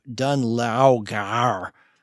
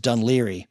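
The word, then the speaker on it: Dún Laoghaire: The Boomtown Rats are from a part of the Dublin area called what you would say if you saw it on a map, Dunlaugar. It is actually pronounced Dunleary.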